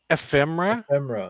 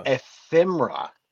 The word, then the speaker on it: ephemera: Ephemera? – Ephemera.